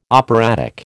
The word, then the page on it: operatic: American English